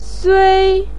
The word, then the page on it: sui1: pinyin